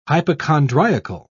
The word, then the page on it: hypochondriacal: British English